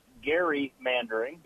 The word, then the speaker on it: gerrymandering: It some people would tell you that it actually should be pronounced gerrymandering because I knew I'd get it wrong.